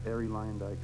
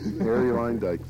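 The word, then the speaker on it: Arie Luyendyk: And again, Harry Leyendijk. – Harry Leyendijk, thank you.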